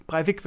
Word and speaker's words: Breivik: Die Norweger trauen um mehr als 90 Menschen, die Anders Behring Breivik in Oslo, heißt der Breivik? Breivik wird er, glaube ich, ausgesprochen, und auf der Insel Utøya getötet hat.